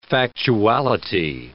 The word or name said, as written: factuality